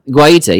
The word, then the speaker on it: Guaita: Guaita, yes.